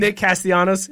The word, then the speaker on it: Castellanos: Nick Castellanos.